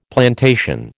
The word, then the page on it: plantation: American English